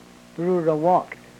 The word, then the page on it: rurowok: Yurok